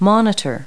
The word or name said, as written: monitor